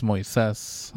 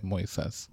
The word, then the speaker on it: Moises: Yes, so it's Moises. – Moises? – It's emphasis on the I, Moises.